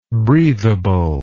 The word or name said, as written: breathableness